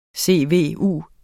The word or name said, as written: CVU